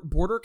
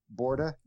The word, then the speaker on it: Borda: How, how's that pronounced? I, I, missed that. Board, Borda count? Uh, Borda, Borda, B O R D A. Oh, okay, Borda.